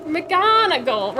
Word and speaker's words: McGonagall: Professor McNoggle. – McNoggle. McGonagall, right how it's spelled right.